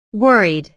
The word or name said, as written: worried